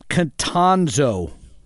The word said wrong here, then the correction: Kontrozo: K-O-N. – Raymond. – K-O-N-T-R-O-Z-O. Contrazo. Pardon me. Raymond L. Contrazo.